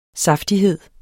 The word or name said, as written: saftighed